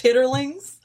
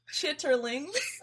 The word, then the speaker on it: chitterlings: It's actually, do you know it's actually pronounced chitterlings? If y'all can see the way Jordan's just so surprised by that. – Wait, I had to take my headphones off. – What? It's pronounced chitterlings. – Okay. Stop it. Don't say that anymore. I don't want to hear that again. – Why do you hate it? – There's too many syllables.